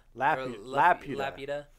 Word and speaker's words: Laputa: Laputa? – Laputa.